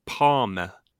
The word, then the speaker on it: Palme: And you pronounce his surname Palme.